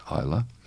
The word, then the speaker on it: Isla: With an S, Isla. The silent S, like